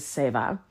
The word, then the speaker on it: Seva: And I've looked up how to pronounce it, and that comes up most consistently is Seva. I have heard Seva, and I always want to say Seva, but that's, I don't know. There's a lot of cultures that use Sanskrit words. So I think that's why you hear different pronunciations, but I'm not completely sure.